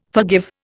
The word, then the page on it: forgive: Indian